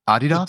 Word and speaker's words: Adidas: Is that how you say it? Adidas? – In Germany, yeah.